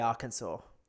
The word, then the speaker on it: Arkansas: Okay, it's the French fault. It's the fault of the French. Because... – Well, everything is the French's fault. We know that. – Of course, but it's spelt the same way, Kansas, Arkansas. But you think about how the French would pronounce Kansas. Mm-hmm. It would be Kansas. So it's Arkansas.